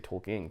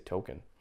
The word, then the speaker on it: Tolkien: I like you say Tolkien because that's how you say it, but I just say Token.